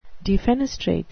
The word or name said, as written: defenestrate